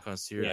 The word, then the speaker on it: Concierge: Concierge.